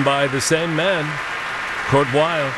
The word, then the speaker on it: Weill: 1934 piece by Kurt Weill, as he was still known then, right. Using the German pronunciation of his name before he settled here in the States.